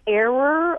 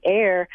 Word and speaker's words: err: You would think it would be like error or errant, but no, the traditional pronunciation for a long time was err. And, you know, William Safire once said that when enough of us say it wrong, we'll be right. And more of us are saying it as air, and I imagine that in another generation it will be air.